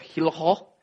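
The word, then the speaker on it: Hillerød: We stayed in the little town of Hillerho. They say it like that. It's got a, like a bit of phlegm in your throat. Hillerho, it's like one of the circles with the line through it.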